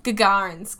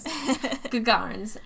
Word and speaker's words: Gagarin: manned space flights prior to how did you say it? – Gagarin's. – Gosh, he's good, guys. Gagarin's.